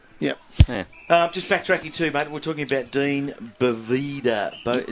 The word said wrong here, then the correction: Dion Boavida: Is that how we say his name? – Dion Boavida. – Dion Boavida. I've got that completely wrong.